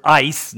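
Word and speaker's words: ice (εἰς): But this is the word ice, not I-C-E, okay? That's how you pronounce the Greek letters, okay?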